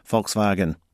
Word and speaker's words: Volkswagen: That's Nita Blake-Person, who's been in Germany long enough now to pronounce it Volkswagen. Sounds so much more authentic.